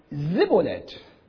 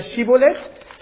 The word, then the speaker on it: Schibboleth: Schibboleth bedeutet übersetzt Strom oder Fluss, also dort an den Furten des Jordan, lag ja nahe, Schibboleth auszusprechen. So, sagte er dann Sibboleth, weil ihm die richtige Aussprache unmöglich war, so ergriffen sie ihn und machten ihn an den Jordanfurten nieder.